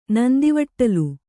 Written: ನಂದಿವಟ್ಟಲು

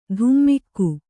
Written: ಧುಮ್ಮಿಕ್ಕು

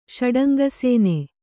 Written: ಷಡಂಗಸೇನೆ

